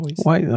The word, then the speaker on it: Wiseau: Wiseau, okej.